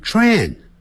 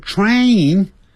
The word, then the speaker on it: train: train 不要念成 train，你念 train 就不对了，发 A 的音 train，先念 train station，啊哈，这样念就对了。By taxi，借由坐乘坐出租车 taxi，啊，这样就这样念啊。Now 就是现在，我现在需要呢打车啊，打这个出租车。那么干什么呢？赶到车站去。哎，时间不够了，只有20分钟时间让我呢赶到车站。Thanks again.